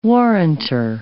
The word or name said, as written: warranter